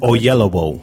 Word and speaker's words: Oyelowo: Oyelowo? – Oyelowo.